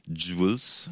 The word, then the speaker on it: Jules: Alors déjà, Jules, c'est parce qu'il s'appelle Julien, tout comme son homonyme marseillais, mais il faut le prononcer à l'américaine. Ça donne Jules, je fais de mon mieux, hein, ce qui veut dire bijou, et c'est d'ailleurs le titre du dernier album.